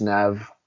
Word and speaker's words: Neve: Neve Campbell, I hope I'm saying that right. Maybe it's Nev.